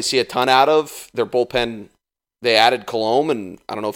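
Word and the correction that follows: Colomé: Colome. – Yeah. Yeah. Pronunciation's not my skill. – So, interestingly enough, though, it's Colome. And you made a slight mistake earlier, which I thought it was this for a long time.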